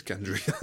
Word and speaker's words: Gendry: Is it Gendry? You know, just stuff like that. Like, we had no idea how to pronounce half of these names. So yeah, just reminded me when he said Gendry. It's like, oh yeah, it is Gendry.